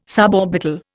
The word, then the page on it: suborbital: Indian